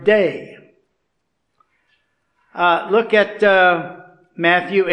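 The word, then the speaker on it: day (δεῖ): Now that word, it's pronounced day.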